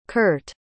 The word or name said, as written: Kirt